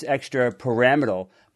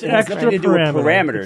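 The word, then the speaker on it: extrapyramidal: Kara, you pronounce that word as extra pyramidal, but this is extra pyramidal. This is a pyramid. – Pyramidal. – Yeah, it's extra pyramidal.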